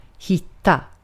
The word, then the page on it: hitta: /ˈhɪtˌa/